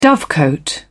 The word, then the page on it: dovecote: British English